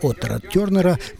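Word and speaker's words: Otha Turner: была навязчивой для Оса Тёрнера, мы еще не раз вернемся. По-английски имя его произносится Оса Тёрнер, но написано оно очень напоминает имя Атар, более нам известное, знакомое.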